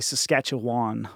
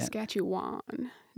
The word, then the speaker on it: Saskatchewan: I think I used to say Saskatchewan, which I'm sure is not right. How do you say Saskatchewan? No, that's, you got a bit of drawl there.